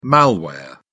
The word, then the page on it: malware: British English